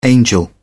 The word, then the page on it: angel: British English